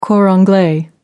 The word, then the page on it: cor anglais: American English